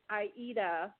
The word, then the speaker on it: Aida: No, it's not Adele. It's Aida. It's basically A-I-D-A.